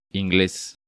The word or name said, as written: Ingles